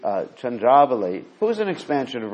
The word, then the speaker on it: Chandravali: Yeah, Chandravali is the right pronunciation.